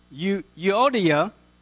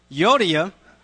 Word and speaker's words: Euodia: Then he says, I urge, let's see how to pronounce her name, Euodia, that's what it said on the tape this morning, Euodia,